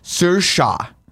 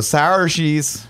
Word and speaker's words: Saoirse: I can't say her name. Saoirse. That's how you say her first Saoirse. – Name. Ronan. – Yeah. Saoirse Ronan. – But every time I see it, I go, sour cheese.